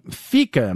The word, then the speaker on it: fika: Fika, am I saying that right? F-I-K-A? – Yeah, so important for Swedes.